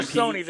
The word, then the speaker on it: Sony: More Sony than Sonny, but yeah. – You pronounce it with your regional dialect.